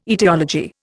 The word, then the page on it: aetiology: Indian